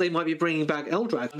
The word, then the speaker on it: Eldrad: I feel like with the crystals, they could have tied it into, and I'm going to get his name wrong, is his name Eldad? – Eldrad.